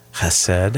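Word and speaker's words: Chesed (חסד): the Hebrew word chesed.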